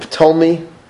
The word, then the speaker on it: Ptolemy: Lycia, in turn, appointed Ptolemy, silent P, Nicanor, and Gorgias, three of Syria's best generals, to lead the Judean campaign.